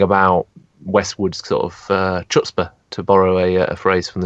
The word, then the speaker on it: chutzpah: I think you need to roll your R a little bit more. It's chutzpah.